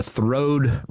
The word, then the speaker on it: throwed: And so Lambert's is now known as the place of the throwed rolls. Yes, I didn't mispronounce that. T-H-R-O-W-E-D, throwed. Says it on the front sign.